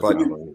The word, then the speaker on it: Ojabo: I got them taking David Ojabo, I think it's how it's pronounced. Yeah, Ojabo.